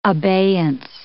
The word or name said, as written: abeyance